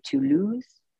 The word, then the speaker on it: Toulouse: Can you pronounce that appropriately? Is it Toulouse? Is it Toulouse? – Toulouse. – Okay.